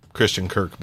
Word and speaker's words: Christian Kirk: You mispronounced Christian Kirk, by the way, a second ago. Christian Cook.